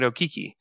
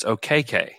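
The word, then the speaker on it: Okeke: They told him, because he was pronouncing it Okiki. They told him, like, you're pronouncing your name incorrectly from your lineage. It's OKK.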